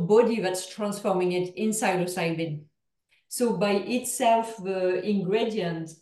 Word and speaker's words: psilocybin: So is the How do you say it? Psilocybin? – Psilocybin, Yeah.